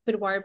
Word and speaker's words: boudoir: So my Instagram is at Boudoir, and it's spelled B-O-U-D-O-I-R. There's like a whole thing about how to pronounce that, by the way. Is it because it's a French word? – Right.